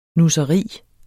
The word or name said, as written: nusseri